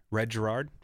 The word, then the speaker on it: Red Gerard: And there's another guy, Red Gerard.